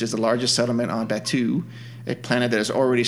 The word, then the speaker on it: Batuu: Batuu.